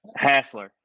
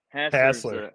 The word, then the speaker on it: Hasler: Hasler. Hasler.